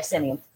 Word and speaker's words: Simion: Yeah, Simeon with an E works too, but Simeon is how you spell it. How you say it. – S-I-M-I-O-N. – Yeah, Simeon.